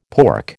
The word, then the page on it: pork: American English